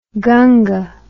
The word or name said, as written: ganga